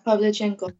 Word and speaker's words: Pavlichenko: And one of them, who I think is the most badass of them all, was Lyudmila Pavlichenko. I think that's how you say her name. – Yeah, Pavlichenko.